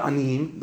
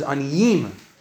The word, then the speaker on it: aniyim: The word's not anim. The word's aniyim. The word's milira.